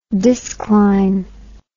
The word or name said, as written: discline